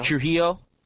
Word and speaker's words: Trujillo: Defendant Heather Trujillo – now, someone pronounce this correctly for me because I know I'm bastardizing it. – Trujillo? Trujillo, maybe, yeah. – No, the J is pronounced at the H.